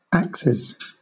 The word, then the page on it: axes: British English